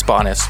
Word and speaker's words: Banis: It's Bonus, but yeah, fuck, I've said it wrong for 5 years.